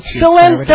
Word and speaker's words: Cilento: Cilento!